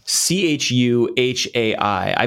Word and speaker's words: Chuhai: Chuhai Labs, is that? It's a Japanese word. They're based in Kyoto. – How do you spell it? – C H U H A I.